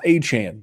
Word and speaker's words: Achane: So you're hoping for that kind of upside with him, but speed, you got to be up, stock up, and it's pronounced Achane, Devon Achane.